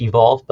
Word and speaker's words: EVQLV: So that's, it's pronounced evolve, but the reason it's EVQLV, it's a common like amino acid kind of like a motif that's common to antibodies on there.